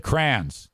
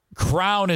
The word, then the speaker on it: crayons: But I have said crayons. Get your crayons. Let's color with the crayons. Crown is out. If you're around someone that says crown, leave that person. It is a crayon. Ben Glaze votes crayons. It's spelled C-R-A-Y-O-N-S.